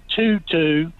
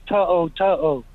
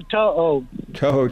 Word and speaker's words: To'o To'o: You know, it ain't Tutu. He ain't dancing with something around his, you know, like a ballerina. His name's To'o To'o. So get it right, Legend.